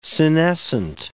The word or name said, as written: senescent